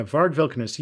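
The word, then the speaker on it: Varg Vikernes: and those are the opinions about Varg Vilkernes, is that right? – Yeah, Varg Vilkernes, he sucks.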